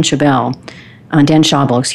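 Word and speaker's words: Schawbel: And then last week, for those of you tuned in, you got to hear from author Dan Schaubel, is how you say his last name.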